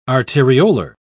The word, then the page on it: arteriolar: British English